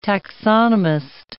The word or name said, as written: taxonomist